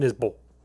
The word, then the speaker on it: Isbell: It's I-S-B-E-L-L. – As a non-Southerner. – Isbell. Isbell. Jason Isbell. – Jason Isbell. – Because I've heard him introduce himself. He's like, hi, my name's Jason Isbell.